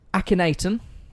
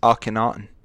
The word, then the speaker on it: Akhenaten: So his parents, his father was Akhenaten. – It's Akhenaten. – Akhenaten. Butchered it already, well done me, pat on the back.